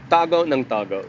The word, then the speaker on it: tago ng tago: They are always tago and tago. D- am I pronouncing this right? Uh, tago ng tago was how you'd say it with a full-on Filipino accent, which is hiding and hiding.